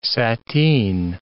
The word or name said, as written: sateen